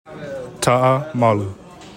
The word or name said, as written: TA'A MALU